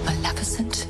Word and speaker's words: Maleficent: Maleficent is a threat to everyone.